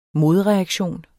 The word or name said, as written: modreaktion